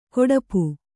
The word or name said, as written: ಕೊಡಪು